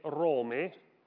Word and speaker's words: ROM: Ma in inglese ROM e ROME, che sta per Roma, hanno la stessa pronuncia, perché entrambe si pronunciano ROM.